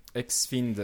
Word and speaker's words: HexFiend: Xfind, alors c'est. Attention la prononciation, c'est H-E-X-F-I-E-N-D. Oui. Voilà, donc euh, c'est pas F-I-N-D ou quelque chose d'autre, c'est F-I-E-N-D. – Non, c'est pas friend non plus.